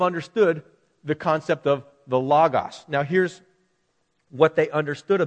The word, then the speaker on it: logos: Well, it's the Greek word, a logos, L-O-G-O-S. We pronounce it logos.